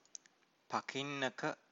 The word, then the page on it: pakinnaka: Pali